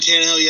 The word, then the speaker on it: Tannehill: He wants to know, what is your expectations for the Titans this year, and why did they sign Tannehill, if I said his name correctly? – Oh, Ryan Tannehill, yes, you did.